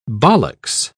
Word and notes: ballocks: British English

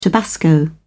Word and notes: tabasco: British English

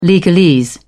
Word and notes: legalese: British English

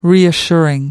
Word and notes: reassuring: American English

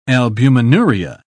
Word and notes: albuminuria: British English

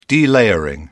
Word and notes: delayering: British English

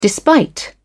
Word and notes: despite: British English

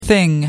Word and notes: thing: American English

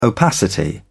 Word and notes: opacity: British English

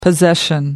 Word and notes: possession: American English

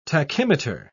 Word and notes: tachymeter: British English

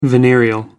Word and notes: venereal: American English

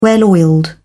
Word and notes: well-oiled: British English

